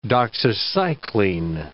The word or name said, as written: doxycycline